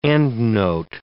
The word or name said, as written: endnote